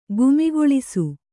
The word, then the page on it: ಗುಮಿಗೊಳಿಸು: Kannada